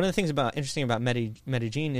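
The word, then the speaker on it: Medellín: If you pronounce it like Spanish, you know, with the double L's, it'd be like Medellin. But colloquially, in there, they pronounce double L's like a hard J.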